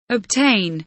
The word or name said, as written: obtain